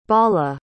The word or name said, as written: bala